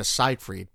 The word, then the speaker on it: Seyfried: We got an email from someone asking us if we can pronounce it Amanda Seyfried, because that's how she pronounces it, and we've been saying Siegfried too long. So thank you for correcting us, but I guess Siegfried is the wrong pronunciation.